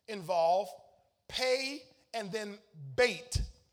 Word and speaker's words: bet: Y'all know the B-E-T. But it's pronounced bait.